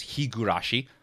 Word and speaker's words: Higurashi: Now, I understand that it's been mispronounced because it's Higurashi,